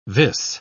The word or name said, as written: vis